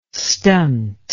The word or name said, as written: stunt